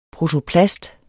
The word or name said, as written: protoplast